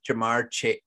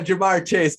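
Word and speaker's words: Ja'Marr Chase: you know, even the one-on-ones with Jamar Chase, I said it right that time. Jamar Chase,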